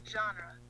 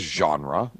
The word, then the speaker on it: genre: Genre. – She says genre. – Like a J. Like a genre. Like a soft J. – Yeah. A soft G. Yeah, even.